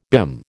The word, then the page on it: BIM: American English